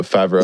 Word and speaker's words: Favreau: Oh, Favreau. – Is that how you say his name? – No, it's Favreau.